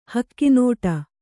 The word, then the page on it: ಹಗಣಾಟ: hagaṇāṭa